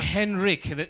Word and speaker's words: Henrik: Henrik. You pronounce it like a K.